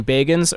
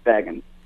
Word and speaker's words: Bagans: Does he actually pronounce his last name Bagans, or was that just something that he did in the intro of his show for a while? – I'm not sure. I think it's Bagans.